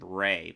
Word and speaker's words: Ralph: He pronounces it Ray, but it's spelled Ralph, so Ray?